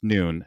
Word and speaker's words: Nuun: So the Nepalese translation is actually pronounced Nuun. It is spelt a little bit differently, by the way. It's not N-U-U-N. It's N-U-N, like none, but it's actually pronounced Nuun.